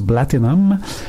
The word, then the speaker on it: BLTNN: Les deux rappeurs sont sur étiquette BLTNN, qu'on prononce Blatinum.